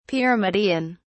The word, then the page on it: pyramidion: British English